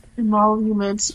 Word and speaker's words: emoluments: Emoluments,